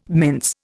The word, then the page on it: mince: Indian